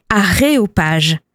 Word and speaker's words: aréopage: Celle que j'apprécie particulièrement, c'est l'aéropage, qu'on doit en fait prononcer aréopage, et qui désigne une assemblée d'experts, un aréopage de spécialistes.